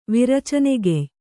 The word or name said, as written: ವಿರಚನೆಗೆಯ್